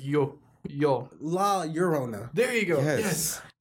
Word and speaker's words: La Llorona: La Llorona. Yeah. La Llorona. – Yes. There you go. Okay. But you can you can say it like La Llorona as well too.